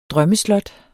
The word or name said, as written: drømmeslot